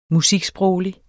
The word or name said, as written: musiksproglig